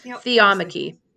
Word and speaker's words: theomachy: Theomachy.